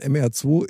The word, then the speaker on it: MR2: MR2. Ja, so, aber so wie die Franzosen das aussprechen, sprechen die das aus als Merd.